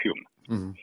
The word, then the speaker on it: Home: Se escribe Home, pero se pronuncia Hume.